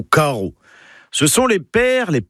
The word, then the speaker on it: karv: Eh bien, aujourd'hui, le papa dans la famille, car Caro, en écriture bretonne moderne, c'est le majestueux Serelaf. Caro, K-A-R-V. Oui, alors le V, enfin, se prononce O. C'est comme ça, faut l'apprendre.